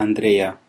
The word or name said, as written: andreia